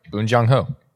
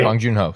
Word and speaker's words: Bong Joon-ho: Bunjo It's, oh man, it's Parasite director. – Bong Joon-ho. – Yeah. – Yep. – He's great. – Bong Joon-ho.